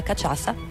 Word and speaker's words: cachaça: Stessa struttura, ma al posto della cachaça. Come cazzo si pronuncia? Quella lì, scusate, questo fallo. Questo fa, come è che si suona il fallo? – Cachaça? – Ecco.